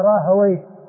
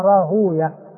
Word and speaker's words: راهويه: بالراهوية عند المحدثين، وبالراهوية عند أهل اللغة، يعني أئمة اللغة يعني يعبرون براهوي، يقولون مختوم بوي، يعني الأسماء المختومة بوي نفطوي سيبوي راهوي، أما المحدثون فإنهم ينطقون بها على أنها راهوية